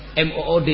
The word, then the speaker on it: mood: Belum lagi kalau kita berbicara tentang mood atau tidaknya, MOOD ya. MOOD, mood ya bacanya.